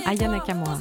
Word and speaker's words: Aya Nakamura: Aka Nakamura. – Aka Nakamura. Aya Nakamura.